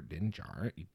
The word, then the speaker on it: Din Djarin: And since then, fans have been anticipating what the next chapter will hold in store for Din Djarin. Din Djarin? Did I say that right? – Yeah.